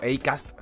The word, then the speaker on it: Acast: O Acast, ¿no? Que es la pronunciación exacta.